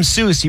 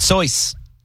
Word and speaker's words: Seuss: If you're calling him Seuss, he pronounces it Soyce.